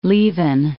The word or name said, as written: Leven, Loch